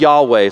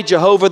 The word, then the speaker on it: Yahweh: The problem with that is that pronunciation is really based off of a misunderstanding of the Hebrew language and some ancient manuscripts. So most scholars today think that we should actually pronounce the Tetragrammaton, God's name, these four letters, something like Yahweh.